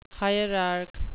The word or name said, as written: hierarch